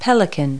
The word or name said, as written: pelican